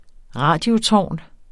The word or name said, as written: radiotårn